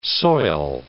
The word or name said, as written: soil